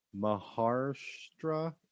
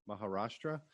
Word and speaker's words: Maharashtra: The scenes depicting Peshawar, Pakistan were actually shot in Khopoli in Maharashtra, India? – Maharashtra. – I butchered that.